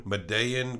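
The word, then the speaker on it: Medellín: But let's go back, Marty back to 27 years earlier in Medellin, Medellin, Colombia.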